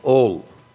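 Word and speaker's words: ol (על): That's ayin lamed. Pronunciation is ol.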